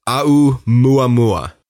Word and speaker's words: Oumuamua: My pronunciation may not be perfect, but it would be pronounced Aumuamua.